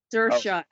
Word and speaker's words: Saoirse: Saoirse.